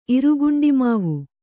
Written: ಇರುಗುಂಡಿಮಾವು

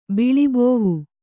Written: ಬಿಳಿಬೋವು

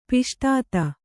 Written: ಪಿಷ್ಟಾತ

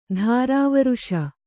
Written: ತಿರುವೇರಿಸು

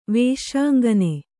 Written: ವೇಶ್ಯಾಂಗನೆ

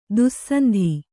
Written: ದುಃಸಂಧಿ

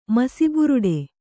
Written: ಮಸಿಬುರುಡೆ

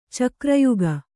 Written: ಚಕ್ರಯುಗ

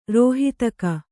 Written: ರೋಹಿತಕ

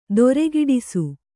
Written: ದೊರೆಗೆಡಿಸು

